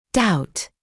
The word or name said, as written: doubt